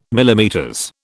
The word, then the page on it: MM: British English